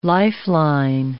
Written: lifeline

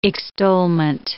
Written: extolment

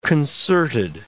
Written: concerted

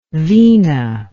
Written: veena

